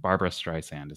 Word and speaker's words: Barbra Streisand: We have a very special topic today to help me get better at and help me, if I'm mispronouncing this, appreciating Barbra Streisand. Is that right? – Yes.